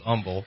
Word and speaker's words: Humble: It's spelled Humble, but the H is silent, so it's Humble, Humble, Texas.